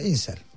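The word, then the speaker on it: incel: Bu İncel miydi neydi? İncel diye mi okunuyor? Bu Caner İncel, İnsel diye mi okunuyor? İngilizce mi okunuyor bu? Ha İnsel. İşte istemsiz bekarlar onun kısaltması herhalde. İnsel diye okunan bir tehlikeye dikkat çeken bir rapor.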